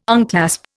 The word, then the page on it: unclasp: Indian